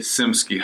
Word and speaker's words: Czimskey: It's Czimskey.